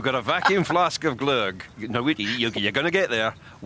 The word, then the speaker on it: Gløgg: Gløgg? – Yes, Gløgg.